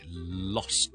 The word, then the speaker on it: lost: 第二个好接近嘅字就系将个 S 同 T 倒转咗，变咗 L-O-S-T, lost, 失去咗嘅 past tense, lost. 大家见到个串法都知道分别喺边啦，一个系先 T 后 S lots，一个系先 S 后 T 是 lost, lost, lost。好啦，发音点教呢？Lost 咧，L O S T 失去咧，我先读 lost 先，好似 L O S S 咁，lost, lost, lost, lost, lost，跟住上去个 T M lost，好容易啫呢个 lost。